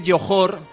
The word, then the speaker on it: Johor: Bueno, se dice Johor, que entiendo que no estáis muy familiarizados con la zona, pero se dice Johor, aunque se escribe con J, pero es Johor, es el príncipe de Johor.